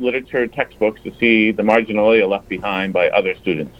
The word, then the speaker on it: marginalia: M-A-R-G-I-N-A-L-I-A. – Marginalia? – Marginalia is how they're saying to pronounce it here.